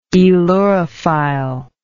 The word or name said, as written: aelurophilic